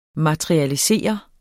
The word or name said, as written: materialisere